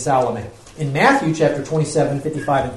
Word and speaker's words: Salome: And Michael said Salome. It's also been pronounced Salome.